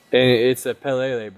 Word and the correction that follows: Palila: bird, correct? I can't pronounce the word, the name right. – Yeah, the Palila.